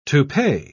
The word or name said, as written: toupee